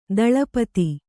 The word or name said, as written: ದಳಪತಿ